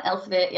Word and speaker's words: Elsevier: Yeah, Elsevier, yeah.